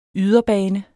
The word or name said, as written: yderbane